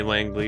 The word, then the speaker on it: Langeliers: And Langeliers, how do you say the name? – the catcher. – Yeah, that Shay Langley.